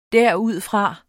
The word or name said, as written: derudfra